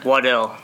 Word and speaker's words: Waddell: Waddell.